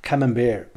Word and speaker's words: Camembert: 呃，还有呢一种叫叫呃 Camembert，Camembert 呢这种 Camembert，我们看那英文字的话，就是很容易把它就喊成叫 Camembert，但实际上它是法语啊，法语呢叫它是法国的这个卡门培尔的软质奶酪，它的这个英文发音跟法语是 copy 来的，所以它不能把那 T 的发音发出来啊，所以叫 Camembert。